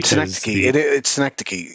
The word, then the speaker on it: synecdoche: Synecdoche is how to pronounce that one.